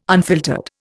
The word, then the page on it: unfiltered: Indian